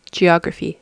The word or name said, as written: geography